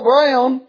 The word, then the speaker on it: Broun: I guess I'll ever forget his last name, because his last name is spelt B-R-O-U-N, like the word noun with a B on it. But Paul Brown said that this week he come out boldly, and I mean he didn't whisper, he come out boldly.